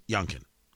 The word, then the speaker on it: Youngkin: Youngkin, pronounced Youngkin.